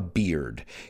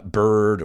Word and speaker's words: beard: He didn't have a beard. Careful with the pronunciation. I've heard a lot of people say uh, bird or bard. It's beard, uh, barba in Espanol.